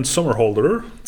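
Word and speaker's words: Somerhalder: Ist das richtig ausgesprochen? Das ist Sommerhalder. Bestimmt irgendwie so Ian Sommerholder. – Ian Sommerholder, 42.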